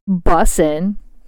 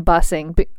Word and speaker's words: bussin: Or bussin. My daughter literally cringes when I say freaking bussing or bussing. See, I put a G on the end.